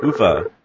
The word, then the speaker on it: Uwe: Uwe. – That would be more in line with the Swedish or German. – Exactly, because the W is usually pronounced as a V.